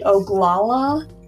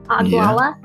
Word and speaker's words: Oglala: But if you do visit Nebraska, you can visit Toadstool Geological Park, which is in the Oglala. How would you say this? O-G-L-A-L-A. – Oglala? – Yeah. – Oglala, that's probably Oglala. Better than Oglala.